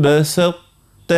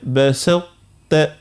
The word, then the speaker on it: بسطت: هنا كي باش نجيو ننطقوها ذي لئن بسطت يعني نركزوا على الطاء من الاول نعطيه القوه لكل الطاء ثم نمشيوا للتاء. لئن بسطت الي بسطت بسطت.